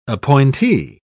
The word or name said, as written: appointee